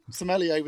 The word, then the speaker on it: sommelier: And preferably a knowledgeable sommelier. Is that how you pronounce it? – It's like a little... I think sommelier was correct.